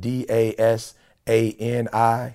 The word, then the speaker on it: Dasani: I think it starts with D A S. A-N-I? – Dasani. – So, yep, it's term, but I'm sure that's what it is. Dasani, $4 for this huge thing of water.